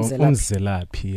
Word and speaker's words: imzelaphi: Hope I pronounced that right. – No. – How do you pronounce it? – How do you spell it? – I-M-Z-E-L-A-P-H-I. – Oh, Mzelapi, yes. It's just your pronunciation is really bad. Mzelapi.